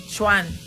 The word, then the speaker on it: Sean: Se pronuncia Chuan, pero se escribe Sean.